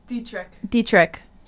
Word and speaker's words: Dietrich: Dietrich.